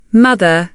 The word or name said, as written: mother